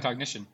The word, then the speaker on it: Cognixion: Yeah, Cognixion.